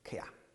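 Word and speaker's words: kia (χ): 其实第七到第十二节呢，他排列起来，我们提过好几次这种犹太人希伯来人的诗歌的表达法，其中有一个叫做 chiastic， chiastic 是希腊文里面的那个 x 那个字呢，他们念成 kia，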